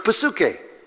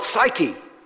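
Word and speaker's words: psuche (ψυχή): And when he uses the word mind, he's using the Greek word, I'll pronounce it in the Greek way first, psuche. You call it psyche. That's the way we would normally transliterate it into English.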